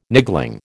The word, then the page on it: niggling: American English